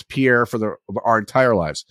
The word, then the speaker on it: Pierre: Is it is it pronounced Pierre or Pierre? It's Pierre. Uh huh.